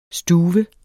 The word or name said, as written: stuve